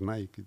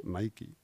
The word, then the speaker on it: Nike: Vas a Estados Unidos y dices que te has comprado una, unas Nike y no te entienden. – ¿Y cómo se dice allí? – Nike. – Ah, Nike. Mm.